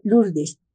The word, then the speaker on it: Lourdes: Se pronuncia Lourdes, en Francia, cerca de España.